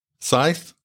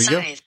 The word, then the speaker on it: Scythe: Scythe. – Okay. There you Scythe. – Go. – So a silent C. – It says the C is indeed silent.